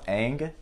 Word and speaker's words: Ng: Andrew Ang. Andrew Ng. – Andrew, but do you know how to say it? – I think it's pronounced Ang.